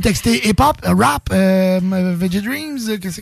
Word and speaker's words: Veggie Dreams: Veggie Dreams.